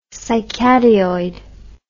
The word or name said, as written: cycadeoid